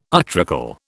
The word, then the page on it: utricle: British English